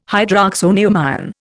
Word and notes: hydroxonium ion: Indian

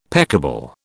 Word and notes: peccable: British English

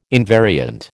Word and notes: invariant: American English

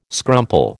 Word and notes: scrumple: American English

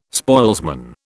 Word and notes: spoilsman: British English

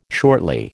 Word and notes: shortly: American English

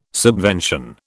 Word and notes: subvention: British English

